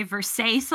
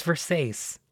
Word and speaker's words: Versace: And we're gonna say Versailles a lot. – Yeah. Yeah. We love um, it it's the official Italian pronunciation. – Yeah. Oh, oh it's Versace.